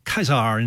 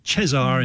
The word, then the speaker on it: Caesar: You know, it's pronounced Caesar in classical Latin and Cesar in medieval or church Latin. But, of course, we say Caesar, of course.